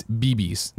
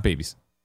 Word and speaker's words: BBs: It's BBs, not babies. BBs. B and B. Capital B, capital B. Not babies, BBs. What are BBs and babies? No, it's just B. Well, he's, I think the pronunciation was babies, but it's BBs. So it's just BBs. So will call it BBs.